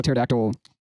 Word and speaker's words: pterodactyl: Because pterodactyl starts with a pee.